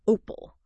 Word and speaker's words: Opal: And this is where a specific tool IDHIN developed comes into focus, Opal, the OpenLink AI layer, pronounced like the gemstone, Opal.